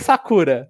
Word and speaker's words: Sakura: E não como a gente aprendeu no Sakura Card Captors. Então, pra mim, eu sempre falei Sakura, mas é Sakura mesmo, né? Meio que a sílaba tônica é no primeiro.